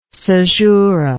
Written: cesura

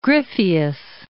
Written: Gryphius

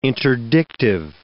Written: interdictive

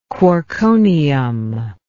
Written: quarkonium

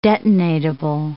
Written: detonatable

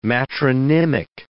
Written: matronymic

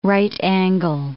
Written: right-angle

